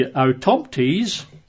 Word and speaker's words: autoptes: So he says of verse 1, whose lives witness to the power of faith, not spectators, for then the word would be autoptes, which is spelled A-U-T-O-P-T-E-S, autoptes, and then he references Luke chapter 1, verse 2.